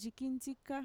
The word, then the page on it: yikintxika: Mixtec